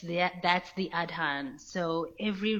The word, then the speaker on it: Adhan: I mean, I want to say the track is The Black Adhan, but I'm correct. – Yes, you are correct. That is the pronunciation, yes.